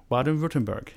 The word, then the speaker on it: Baden-Württemberg: Baden-Württemberg.